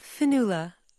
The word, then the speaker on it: Fionnula: It is spelled F-I-O-N-N-U-L-A. – U-A-L-A. – All right, I beg your pardon. Oh, now I know how to pronounce it. – I know, that extra A really gave it away.